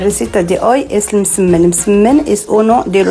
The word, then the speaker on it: msemen: Es una palabra complicada, decía, es M-S-E-M-E-N. Msemen, msemen, vale. Y se pronuncia.